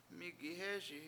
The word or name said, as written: MIIgihEESi